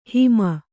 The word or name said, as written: ಹೈಮ